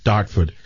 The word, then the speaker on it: Dartford: He's from Dartford, United Kingdom. Did I say that right? – Dartford.